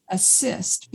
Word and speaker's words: ACISTE: Yeah, but the acronym is ACISTE, pronounced assist, because their whole goal is to assist people who've had transpersonal experiences and need to integrate them.